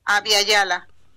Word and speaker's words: Abya Yala: Abya Yala. – Abya, se escribe A-B-Y-A. – Y-A-L-A, ajá.